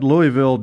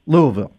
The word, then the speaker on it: Louisville: You know, they had Louisville down 18 in the second half. – Louisville. – There you go. Sorry. Get the right pronunciation.